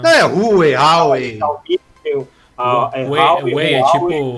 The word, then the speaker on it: Huawei: A gente não fala o H, é Huawei, velho. – Da, não é Huawei, do... Huawei.